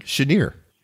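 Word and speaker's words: Cheniere: Is it Chenier? – Chenier. – A Chenier. – We need to work on you and your Cajun name pronunciations.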